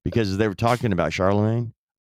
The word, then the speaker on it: Charlemagne: He couldn't pronounce Charlemagne. He called him Charlie Mange. Charlie Mange, Mark.